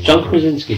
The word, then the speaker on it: Krasinski: Quick bit of news to conclude in this part two is how do you pronounce his name? – John Krasinski.